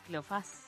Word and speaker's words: Cleofás: Y dato curioso, no, Cleofás, tiene acento en la A, sí, Cleofás.